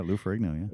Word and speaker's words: Lou Ferrigno: Lou Ferrigno? – Oh, sure, yeah. – The Hulk, right? – Yeah, yeah. – Did yeah. – I say that right? – Yeah, Lou Ferrigno.